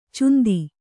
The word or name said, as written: ಚುಂದಿ